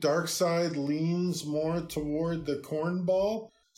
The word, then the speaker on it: Darkseid: I always knew it as Dark Sea. – Right? – But all of a sudden, like, since it's been pronounced, like, in the media, and you hear it pronounced in the media, it usually is Dark Side.